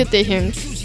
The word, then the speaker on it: Taehyung: Encima ni siquiera se pronuncia Tae, se pronuncia Taehyung.